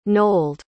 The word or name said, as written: nold